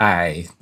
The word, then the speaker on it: IJ: So you have, in the case of Amsterdam, you have the Amstel River draining into this wide bay, which is called the I. The Dutch language has a vowel that English doesn't have, which is written as IJ, both capital, and it's pronounced I.